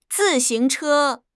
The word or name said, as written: zi4 xing2 che1